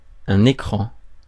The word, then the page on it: écran: France; [e.kʁɑ̃]